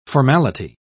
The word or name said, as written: formality